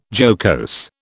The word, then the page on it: jocose: British English